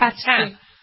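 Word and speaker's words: tarte Tatin: How do you pronounce it? Tartatin.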